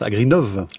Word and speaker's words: Agrinov: Et vous êtes aussi responsable du projet Agrinov, c'est comme ça qu'on le prononce, Agrinov. – Agrinov, hein, oui.